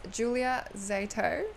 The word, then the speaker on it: Julia Zieto: It is Julia Zieto. – Okay. – It's very hard to pronounce. – Yeah, so it's Zieto. – Yes, that's correct.